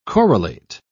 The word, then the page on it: corollate: British English